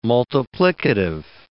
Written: multiplicative

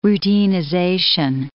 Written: routinization

